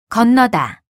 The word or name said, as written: 건너다